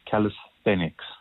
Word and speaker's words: calisthenics: It's calisthenics. – What is calisthenics? – So the term calisthenics, basically, it's directly derived from ancient Greek.